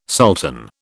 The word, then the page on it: sultan: British English